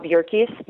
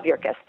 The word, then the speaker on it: Yerkes: Well, I was really lucky that there is this amazing stuntman named Bob Yerkes, rhymes with circus, Bob Yerkes.